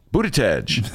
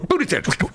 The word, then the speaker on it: Buttigieg: And how do you pronounce that guy's last name? – Buttigieg. – There you go. – Shake your Buttigieg.